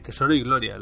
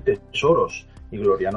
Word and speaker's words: Tesoro y Gloria: Del juego de rol eh, Tesoro y Gloria. Lo he dicho bien, ¿no? – Efectivamente. De hecho, creo que eres de los primeros que lo dice bien porque la gente suele decir tesoros y gloria.